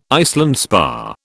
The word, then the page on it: Iceland spar: British English